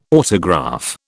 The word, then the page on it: autograph: British English